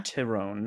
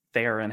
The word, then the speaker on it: Theron: I read that her last name in Afrikaans, because she's from South Africa, is actually Tyrone. – Tyrone, yeah. – Tyrone, not Theron, how we say it.